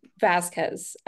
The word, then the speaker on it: Vazquez: Yeah, so it's Vazquez.